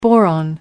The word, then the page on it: boron: American English